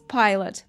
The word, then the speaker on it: Pilate: И здесь, внимание, да, как читается на самом деле на английском Понтий Пилат, Pontius Pilot. Pilot как пилот самолета.